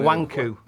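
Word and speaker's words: Nwankwo: Pronounce his Christian name for the bonus. – Nakawanda Canu. – Nawanku.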